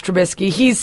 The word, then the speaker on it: Trubisky: Yes, how do you pronounce it? – Trubisky. – Mitchell Trubisky, yes.